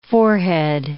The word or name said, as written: forehead